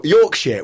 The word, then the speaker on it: Yorkshire: Also, the woman said, Yorkshire. We say Yorkshire. It's called Yorkshire, but we don't pronounce the H.